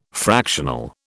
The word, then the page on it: fractional: British English